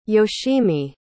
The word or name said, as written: yoshimi